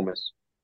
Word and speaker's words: Metz: Le T ne se prononce pas pour Metz, un peu de respect pour la Lorraine, même si j'en ai que très peu pour Metz.